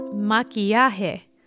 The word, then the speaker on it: maquillaje: Makeup in Spanish is called maquillaje. So now let's try saying it all together. Ready, amigos? Maquillaje. – Maquillaje. – Maquillaje. – Maquillaje.